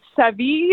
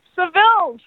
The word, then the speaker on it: Seville: A lot of people mispronounce it like Savvy. We're like, no, Seville.